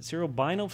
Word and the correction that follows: cerebrospinal: fluid? It's cerebrospinal.